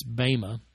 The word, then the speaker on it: bema: Really, it's bema, to be pronounced as is traditionally pronounced with Greek words and the long eta sound.